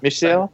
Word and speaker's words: Michel: Michel.